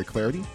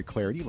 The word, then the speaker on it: Clardy: I'm indeed Troy Clarity on Twitter at Troy Clarity. Last name is spelled C-L-A-R-D-Y.